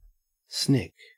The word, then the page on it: snick: Australian English